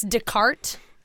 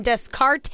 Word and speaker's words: Descartes: You like how I can pronounce Descartes? – That's fine. I looked it all up. – I'm over here like, yeah, I have a very good education. Descartes.